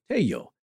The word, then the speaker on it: Tello: Now, he was a student of a man known as the father of Peruvian archaeology, Dr. Julio Cesar Tello. And that's T E L L O. So, in, uh, in Spanish, you would uh, pronounce as Y. Uh, sound.